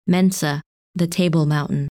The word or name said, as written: Mensa